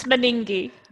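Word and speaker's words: Meningie: Is it Meningi? How do you pronounce it? – It's Meningi. – Meningi. – Yes.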